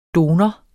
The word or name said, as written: donor